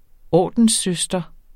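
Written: ORDENSSØSTER

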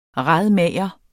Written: radmager

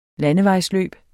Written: landevejsløb

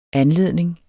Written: anledning

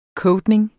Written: coating

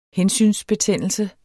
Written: hensynsbetændelse

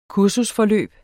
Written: kursusforløb

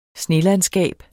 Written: snelandskab

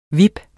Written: VIP

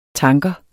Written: tanker

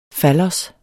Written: fallos

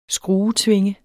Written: skruetvinge

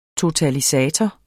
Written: totalisator

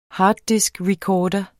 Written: harddiskrecorder

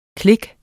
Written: klik

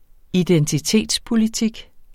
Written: identitetspolitik

